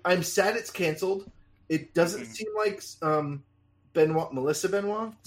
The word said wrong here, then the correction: Benoist: Is that right? Am I saying her name right? – I think it's Benoist, actually. Benoist? – Okay.